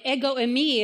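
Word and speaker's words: ego eimi (ἐγώ εἰμι): The I am, ego eimi is how you say it in Greek,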